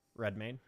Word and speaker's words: Redmayne: Redmayne.